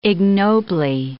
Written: ignobly

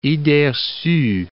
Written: idees recues

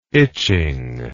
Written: itching

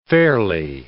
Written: fairly

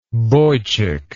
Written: boychik

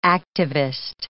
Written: activist

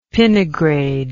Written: pinnigrade

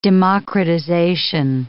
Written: democratization

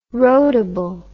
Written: roadable

